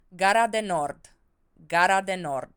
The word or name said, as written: Gara de Nord